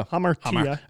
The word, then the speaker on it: hamartia: It's, you know, the Greek word is hamartia. Bless you. I say that right? – Yeah. – Hamartia. – I think you need a little more like hammer in it. Yeah. You know, hamartia.